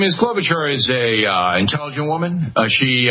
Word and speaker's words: Klobuchar: Klobuchar, yeah.